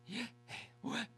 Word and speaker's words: Yahweh: In Hebrew there are no vowel sounds, so it's just four letters, YHWH, or loosely pronounced, Yahweh.